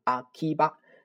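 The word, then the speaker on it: アキバ: 啊，阿基巴，阿基哈巴拉啊，然后呢，简称叫做阿基巴啊，A K I B A，阿基巴。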